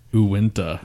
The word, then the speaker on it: Uinta: Uinta.